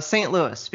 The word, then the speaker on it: St. Louis: St. Louis, because we're Americans and we don't know how to speak French.